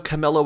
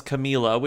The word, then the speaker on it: Camila: Number one, I didn't know was Horegi. Also, I didn't know Camilla was Camila, which is nice to know. So we have correct pronunciations.